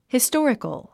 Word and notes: historical: histɔ’ːrikəl; ヒストリカル

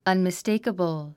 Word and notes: unmistakable: ʌnmistéikəbl; アンミステイカブル

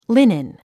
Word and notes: linen: línin; リネン

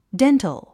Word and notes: dental: déntl; デントゥ

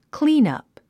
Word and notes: cleanup: klíːnʌ̀p; クリーナプ

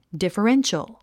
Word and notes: differential: dìfərénʃəl; ディファレンショゥ

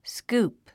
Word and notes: scoop: skúːp; スクープ

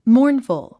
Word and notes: mournful: mɔ́ːrnfəl; モーンフゥ

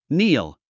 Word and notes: kneel: níːl; ニール

